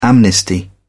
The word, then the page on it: amnesty: British English